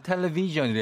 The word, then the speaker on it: 텔레비: 텔레비 해야지 한국어인데, 테레비 하면 일본어이거든요.